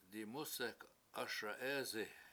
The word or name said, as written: dimUsek7 ASa7EEsi